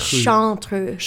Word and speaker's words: Chartreuse: Chartreux. – Well, I think, it's kind of pronounced, Chartreuse. – Chartreuse. – Yeah, like a chartreuse, like an N, but there's not an N in it.